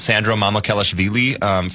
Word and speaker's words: Sandro Mamukelashvili: But a guy that I like is Sandro Mamukelashvili from the Toronto Raptors.